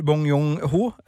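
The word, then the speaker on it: Bong Joon-ho: Jeg syns at eh, regissør Bong Yong-ho, eh, hvis det er riktig uttale Det er riktig nok.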